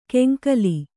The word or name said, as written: ಕೆಂಕಲಿ